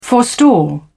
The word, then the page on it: forestall: British English